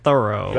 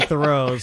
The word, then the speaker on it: Thoreau: I did hear from the clip we played she didn't know how to pronounce Thoreau. But she kept saying Thoreau.